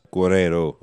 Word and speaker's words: Guerreiro: Guerreiro, of course. I said Guerreira earlier before the pronunciation police are on to me.